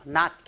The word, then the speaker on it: knot: knot, K가 묵음인데, 고리예요.